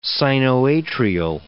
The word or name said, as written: sinoatrial